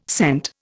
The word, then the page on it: scent: Indian